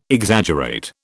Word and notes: Exaggerate: British English

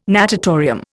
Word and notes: natatorium: Indian

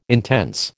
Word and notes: intense: American English

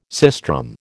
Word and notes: sistrum: American English

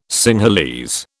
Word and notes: Sinhalese: British English

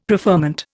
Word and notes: preferment: Indian

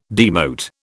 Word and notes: demote: British English

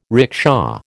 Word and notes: rickshaw: American English

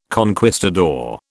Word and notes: conquistador: British English

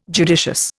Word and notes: judicious: Indian

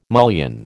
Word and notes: mullion: American English

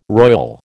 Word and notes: royal: American English

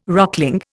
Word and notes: rockling: Indian

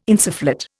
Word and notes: insufflate: Indian

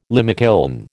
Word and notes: limekiln: American English

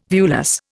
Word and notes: viewless: Indian

